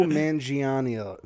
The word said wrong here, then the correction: Manganiello: Manganiello. – Yeah, that one.